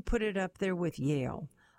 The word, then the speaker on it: Yale: Yale rhymes with stale.